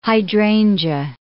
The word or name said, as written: hydrangea